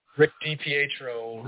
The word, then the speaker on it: DiPietro: Rick DiPietro.